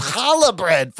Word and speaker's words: challah: Challah, it's pronounced challah bread.